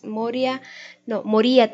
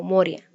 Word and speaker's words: Moria: en cuanto a rayo por el maestro ascendido Moria, Moría también lo pronuncian, ya ahí varía, yo francamente lo pronuncio como Moria, me suena con más familiaridad.